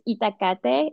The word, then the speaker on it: Itacate: It's Itacate. It comes from the Nahuatl itacatl, and that's the, the language of the Aztecs,